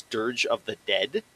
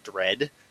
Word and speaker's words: Dirge of Dread: I always thought it was Dirge of the Dead. I literally added an extra word in there and pronounced it as dead, not dread.